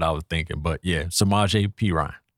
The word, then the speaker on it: Samaje Perine: We got Samaje Perine. – Yes, that's Joe Mixon's backup. – Oh, I said that right? – You did.